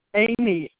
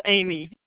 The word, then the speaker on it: Aimee: Amy, A-I-M-E-E. Flores, F-L-O-R-E-S. – Okay, can you spell that first and last name again for me? The phone was breaking up. – My first name is Amy, A-I-M-E-E. My last name is Flores, F-L-O-R-E-S.